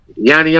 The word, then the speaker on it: Johnny: Yanni Johnson. He was Swedish. – Yeah, you gotta say it correctly, you know, Yanni.